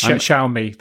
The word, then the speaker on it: Xiaomi: Xiaomi? – Yes, yes, Xiaomi.